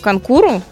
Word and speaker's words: конкур: А к началу 20 века практически все европейские страны проводили у себя национальные соревнования по конкуру, правильно же ударение поставила? То вроде как так. – Все правильно.